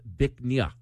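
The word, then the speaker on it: Bích Ngà: It's spelled B-I-C-H-N-G-A. It's pronounced Bic Nha.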